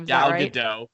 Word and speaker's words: Gal Gadot: That Imagine song sung by all of those random celebs like Gal Gadot. I don't know how to say her name. – Gal Gadot.